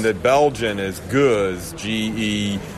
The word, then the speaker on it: Gose: You pronounce it Guz. A Guz would be the French pronunciation. – Oh, he's going French. – Because I heard Gosa. Is Gosa okay for an Anglo?